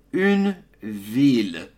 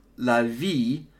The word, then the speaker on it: ville: Alors, en français, le mot ville, on prononce les deux L, une ville. Tu dois absolument prononcer les L. Si tu ne prononces pas, ce n'est pas bon. Donc, ce n'est pas la vie. Parce que si tu dis la vie, you're saying life.